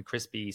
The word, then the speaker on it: Chrispy: Yeah, Instagram and most other socials on at Dr. Crispy Music, and Crispy spelled like the name Chris. I'm really great at SEO. No, just joking. So DR and then CHRISPY music. Yeah, so Chris with a PY on the end.